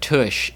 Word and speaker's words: tush: Also, the word, according to the Wikipedia article, the word tush is pronounced in the song in such a way that it rhymes with the word rush, resulting in a monde green, which has some listeners believing the word is touch if they haven't read the lyrics.